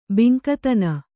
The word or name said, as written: ಬಿಂಕತನ